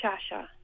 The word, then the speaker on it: Sciascia: And how do you say the name of your bass player? – Johnny Shasha. – Which is spelled S-C-I-A-S-C-I-A.